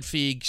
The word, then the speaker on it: Feig: Again, it's spelt like it might be Feig, but I was looking at a pronunciation key and it said Feig, so I'll say it that way tonight.